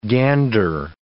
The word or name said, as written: gander